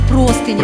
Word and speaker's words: простыня: А как правильно говорить, простыня или простыня? – Простыня. А если вот трудное слово поставить в стишок, сразу будет все ясно. Смотри, стелят на матрас меня, называюсь простыня. Когда мы на машине летим во весь опор, то нас везет не шофер, а нас везет шофер.